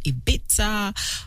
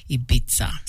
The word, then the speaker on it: Ibiza: And there's controversy sometimes that comes up on the correct pronunciation of, is it Ibiza or is it Ibiza? But apparently, where Venga Boys are from in the Netherlands, the pronunciation is Ibiza. So the two can be correct.